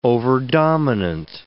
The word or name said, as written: overdominant